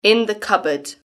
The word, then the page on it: in the cupboard: British English